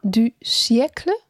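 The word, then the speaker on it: fin de siècle: Fin du siècle? – Ja.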